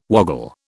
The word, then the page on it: woggle: British English